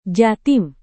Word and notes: jatim: Indonesian

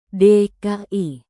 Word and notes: dki: Indonesian

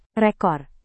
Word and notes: rekor: indonesian

